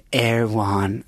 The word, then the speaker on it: Erewhon: Somebody in my DMs was like, it's pronounced Erewhon.